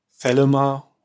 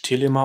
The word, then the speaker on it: Thelema: Also er war schon ein krasser Typ, aber ich, ja, ich interessiere mich für Thelema oder Deutsch Telema ausgesprochen, ohne TH.